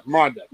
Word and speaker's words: Miranda: Miranda.